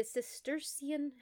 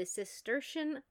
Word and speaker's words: Cistercian: Like Cistercian? Like a cistern? – Like a cistern.